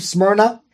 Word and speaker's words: Smyrna: Ignatius, in his book, the letters, in his letter to the Smyrnaeans, remember the Church of Smyrna? That's how you say it.